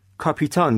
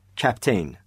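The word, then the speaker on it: captain: کاپیتان گفته میشه یا با تلفظ درستتر کپتین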